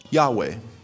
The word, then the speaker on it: Yahweh: that's there in place of four Hebrew letters that we would call YHWH. We might pronounce that Yahweh. And the name means "I am." And the reason it is spelled Lord, L-O-R-D,